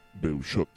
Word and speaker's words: Beuchot: Beushot.